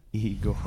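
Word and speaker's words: Igorrr: It's pronounced Igor. – Oh, okay. Oh, shit. I fucked it all up. Igorr. – Rrr. – Three R's.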